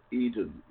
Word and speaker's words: Eden: If I can't find you, you can find me on Facebook as Bob Eden, E-D-E-N, like in the Garden of Eden.